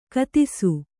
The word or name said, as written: ಕಥಿಸು